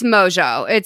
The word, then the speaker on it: Mongeau: It's Mongeau. It's French, the way it's like pronounced.